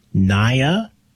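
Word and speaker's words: Niia: You're listening to CKDU 88.1 FM, and that was the song Black Dress by the artist Naya, I think is how you pronounce her name. It's N-I-I-A, so Naya, I guess the two I's makes it a long I sound, Naya.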